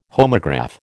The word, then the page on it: homograph: American English